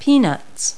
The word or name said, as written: peanuts